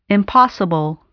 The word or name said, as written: impossible